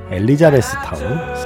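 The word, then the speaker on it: Elizabethtown: 매직아웃 스페셜 M 김신의 음반가게 오늘은 카메론 크로우 감독의 영화 엘리아베스 타운 네, 발음이 잘 안 되네요. 엘리자베스 타운 사운드 트랙 함께 했습니다.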